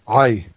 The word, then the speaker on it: Ai: While he takes it and he hides it, he stashes it under his tent, The men go into battle against, now how do I pronounce, is this Ai? – Ai, yes, Ai. It's the city of Ai.